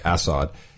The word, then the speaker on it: Assad: Assad is—he's doing interviews, and he's like, "Oh, this is really not very good.